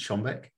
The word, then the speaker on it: Schönbeck: You can find me on LinkedIn under my name, Pete Schönbeck.